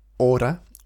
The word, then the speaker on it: hora: ¿Qué hora? Hora, H-O-R-A, with a silent H.